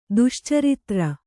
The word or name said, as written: ದುಶ್ಚಾರಿತ್ರ